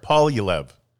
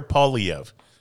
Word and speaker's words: Poilievre: And how do you say the conservative leader's name? – Pierre Paul. – There we go. – Pierre Poilievre. – Really? – Yeah. Pierre Poilievre. – You just said it two different ways. – Okay. Well, Pierre. Everybody's calling him Pee-Pee, and he's very upset about that. – You don't know how to, you don't Pierre even know how to say his name. – Oh, there it is. Pierre Poilievre.